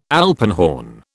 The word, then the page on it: alpenhorn: British English